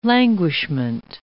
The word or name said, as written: languishment